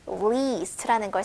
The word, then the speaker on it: least: At least, 적어도라는 뜻이고, least라는 걸 살짝 길게 least 이렇게 발음하는 게또 포인트인 표현이고요.